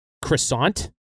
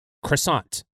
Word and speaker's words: croissant: But we've transliterated it, and it's like we've like copycatted the word, but we've put an English pronunciation on a French word. – Yeah. – And so we say croissant, And like, arguably, we kind of can't pronounce it the same way because we're not used to French pronunciation.